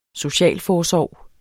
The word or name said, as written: socialforsorg